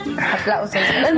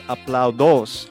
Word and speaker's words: aplausos: Aplausos. – ¿Por qué no me corregiste? – So she pronounced the word applause as aplaudos instead of correctly aplausos.